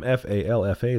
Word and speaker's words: Falfa: I am F A L F A. That's pronounced Falfa.